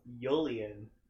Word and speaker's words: Yulian: Lillian, Julian. – No, Yulian. It's a Y.